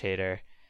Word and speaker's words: Hader: I love Josh Hader. Did I pronounce his name right? – Yes.